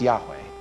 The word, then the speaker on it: Yahweh: In Hebrew, it's spelled YHWH, probably pronounced Yahweh.